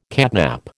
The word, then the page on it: catnap: American English